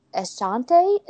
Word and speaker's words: Ashanti: The last one, the singer Ashanti. Is that how you say her name? – Yep.